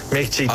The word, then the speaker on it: Michelin: Michelin.